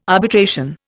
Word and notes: arbitration: Indian

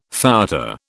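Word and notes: fouetté: British English

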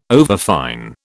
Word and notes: overfine: British English

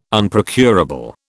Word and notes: Unprocurable: British English

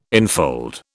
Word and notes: enfold: British English

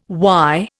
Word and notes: why: Indian

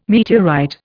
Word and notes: meteorite: Indian

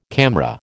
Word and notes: CAMRA: American English

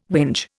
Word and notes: whinge: Indian

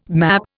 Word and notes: map: Indian

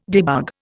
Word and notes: debug: Indian

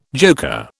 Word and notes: joker: British English

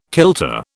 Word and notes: kilter: British English

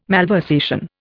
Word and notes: malversation: Indian

